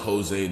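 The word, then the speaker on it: José: I stay with my Mexican plug and that nigga named Hoes. Bruh, I think it's pronounced Jose, not Hose.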